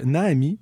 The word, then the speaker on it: Naami: On poursuit encore en nouveauté avec Naami. Ça s'écrit N-A-A-M-I.